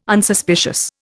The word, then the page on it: Unsuspicious: Indian